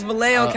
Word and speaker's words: Vallejo: Yes, Vallejo, California.